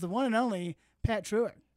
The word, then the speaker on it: Treuer: That's the old American way. Then the German-Swiss pronunciation is Treuer. – Treuer.